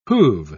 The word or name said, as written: who've